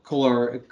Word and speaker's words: corollary: Good that they, you know, yeah, that it would be the Adam Cole corollary.